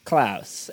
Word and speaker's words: Klaus: And it is time to talk about Klaus. And I do believe that is the correct pronunciation of this film, because that is his name.